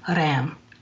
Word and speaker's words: Ram: The mantra for the solar plexus is R-A-M, pronounced with a roll to the R as in Ram. And during meditation, it's drawn out like this.